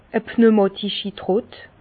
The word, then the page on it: e pneumatischi Trott: Bas-Rhin; Herrlisheim